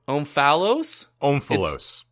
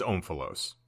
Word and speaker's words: Omphalos: I'm going to butcher this, but the Omphalos? – Omphalos. It is Omphalos.